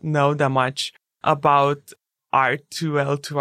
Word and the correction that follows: RTL2: Well, RTL2, it's how we pronounce it in English, is one of the private TV stations in Germany.